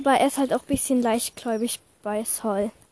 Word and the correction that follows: Saul: Eigentlich spricht man ihn Saul aus, habe ich schon mal gesagt.